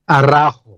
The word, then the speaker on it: Araujo: Araujo.